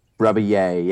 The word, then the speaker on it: Rebillet: Rebillet? – Rebillet, yeah.